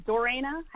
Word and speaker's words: Dorena: Dorena? How do I spell that? – D-O-R-E-N-A.